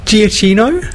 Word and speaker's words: Giacchino: Is it not Giacchino? – Yeah, it is.